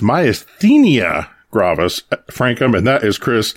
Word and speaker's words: myasthenia gravis: This evening, eventually, we're going to be talking about myasthenia gravis, and I say it like that because, well, that's how it's pronounced.